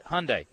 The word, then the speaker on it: Hyundai: It's like when you're talking to your wife, you say, hun, and then you say, what day is it? Hun, what day is it? Hyundai.